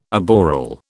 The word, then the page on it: aboral: British English